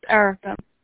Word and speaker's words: Erica: My name is Erica. – How do you spell it? – E-R-I-C-A.